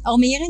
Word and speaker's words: Almere: So when this new clinic opens in Almere, am I saying that correctly? – Almere, yeah, in the Netherlands.